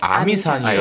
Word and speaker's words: 아미산: 예, 어메이산이라고 하기도 하고 아미산이요. – 아미산. 아, 우리나라 발음 또 아미산이라고 아미산.